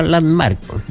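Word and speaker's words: Landmarq: Se pronuncia Landmark, tal cual, pero con K y se escribe con Q.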